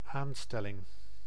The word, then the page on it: appointment: American English; [ə.ˈpɔɪnt.mənt]; /ʌˈpɔɪnt.mɛnt/; /əˈpɔɪnt.mɪnt/; [əˈpʰɔɪ̯̃ʔmɪnʔ]; [əˈpʰɔɪ̯nʔmɪnʔ]